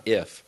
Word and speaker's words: if: Like, why am I pronouncing an N? Why is it not if?